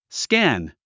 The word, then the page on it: scan: skǽn; スキャン